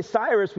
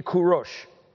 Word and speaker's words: Kurosh: So we don't say Cyrus, we say Kurosh. So now, obviously it doesn't make sense in English because you're like, where do you get Kurosh from Cyrus? That's C-Y-R-U-S, right?